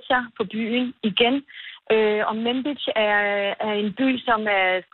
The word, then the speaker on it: Manbij: Og den amerikanske koalition i Syrien bekræftede i går, at den har trukket styrker ud af byen Manbij. Udtaler det korrekt? – Manbij. Ja.